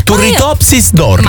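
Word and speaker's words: Turritopsis dohrnii: Ma guarda che l'avevi detto bene, Turritopsis dordorni.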